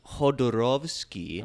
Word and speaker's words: Jodorowsky: So his whole name is Alejandro and it's pronounced Jodorowsky.